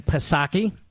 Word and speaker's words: Psaki: But I want to circle back to quote the late, great Jen Psaki. They tell me the P is silent.